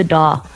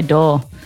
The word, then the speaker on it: DAW: Behind the DAW. That's how you say it. Behind the door. Behind the DAW. – The DAW. – We just say DAW. Oh. It sounds like a door, but like, duh, I don't know.